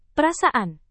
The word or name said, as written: perasaan